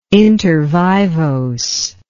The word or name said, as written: intervivos